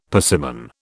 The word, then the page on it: persimmon: British English